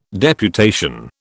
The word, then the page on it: deputation: British English